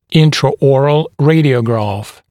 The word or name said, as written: intraoral radiograph